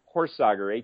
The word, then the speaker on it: Horsager: But it's David Horsager, Horsager, and you can find that on Twitter, Facebook, and LinkedIn, and everywhere else.